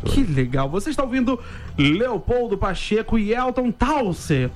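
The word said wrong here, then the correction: Towersey: Falei certo? – Quase. Towers-y.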